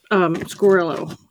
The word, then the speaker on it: Squirrello: Squirrello. Whatever. – You said it right. – I did. – The great Squirrello.